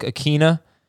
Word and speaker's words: Ikhine: Nick Westbrook, if you can't find him, I think he is Nick Westbrook in our database, but it's Nick Westbrook Akina. It's something like I-K-H-I-N-E. – Yeah, nailed it.